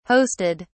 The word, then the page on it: hosted: British English